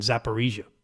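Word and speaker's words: Zaporizhzhia: Zaporizhia, I believe I heard this morning on the news, John, is the pronunciation Zaporizhia.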